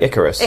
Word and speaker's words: IKAROS: I don't know if I pronounced that right. Icarus? – Mm, sounds like Icarus.